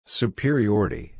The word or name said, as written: superiority